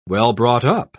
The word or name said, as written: well-brought-up